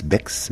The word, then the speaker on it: Bex: À Bex, c'est une ville qui s'écrit B-E-X, Bex, mais qui ne se prononce Bex, et qui est située dans la vallée du Rhône entre Montreux et Martigny, non loin d'Aigle.